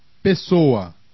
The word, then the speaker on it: Pessoa: A double S between vowels is like the English S of hiss. – Agressivo. – Congresso. – Depressa. – Impossível. – Interessante. – Passado. – Pessoa.